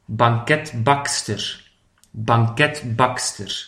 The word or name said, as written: de banketbakster